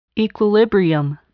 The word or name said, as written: equilibrium